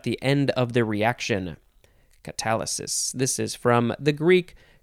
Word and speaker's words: catalysis: It is catalysis. That's not how you say it. Catalysis. C-A-T-A-L-Y-S-I-S, catalysis, noun from 1836,